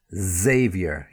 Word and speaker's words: Xavier: And then somebody's name, Xavier, yeah? Or sometimes we put a little Xavier, yeah? We pronounce it in that way as well. So begins with the letter X, but you've got that Z sound.